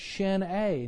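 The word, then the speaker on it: Sin É: It looks like Sin E, but in Gaelic it's pronounced Shin A.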